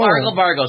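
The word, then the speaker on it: argle-bargle: Oh, argle bargle. Those are L's. – Oh, well. Argle bargle. That's a drinking word for boys. – But in her pronunciation, she put argy bargy.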